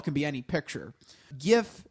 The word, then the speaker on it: GIF: He said it's a soft G, pronounced GIF.